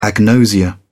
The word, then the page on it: agnosia: British English